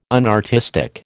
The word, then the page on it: unartistic: American English